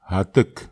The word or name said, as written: atek